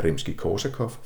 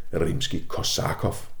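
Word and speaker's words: Rimski-Korsakov: udtale navnet på komponisten Nikolaj Rimski korsakov forkert, nemlig med tryk på næst sidste stavelse Rimski korsakov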